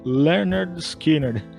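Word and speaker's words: Lynyrd Skynyrd: o título do álbum é exatamente pronúncia Leonard Skinner.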